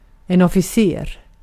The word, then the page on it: officer: /ˈɒf.ɪ.sə/; /ˈɑfəsəɹ/; /ˈɑf.ə.sɚ/; /ˈɑfəsəɻ/